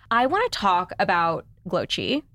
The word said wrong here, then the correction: Glōci: Is that how you say it? – Glossy. – Glossy. – Yep. – Oh, close.